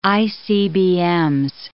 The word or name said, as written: ICBMs